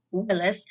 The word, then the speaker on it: Willis: which is Lakita Willis. And my first name is spelled L-A-K-E-T-T-A. Last name is just like Bruce Willis, W-I-L-L-I-S.